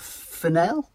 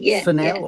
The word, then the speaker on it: Fennell: Is a film by, I know we disagree on the pronunciation, but Emerald Fennell? – Fennell. – Or fennel. I mean, I like to call it fennel because I like to think she's a semi-precious stone, or a precious stone, and a herb.